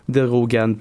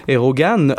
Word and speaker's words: Erdogan: Erdogan, pardon, toujours aussi difficile de le prononcer. – Erdogan.